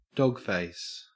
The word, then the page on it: dogface: Australian English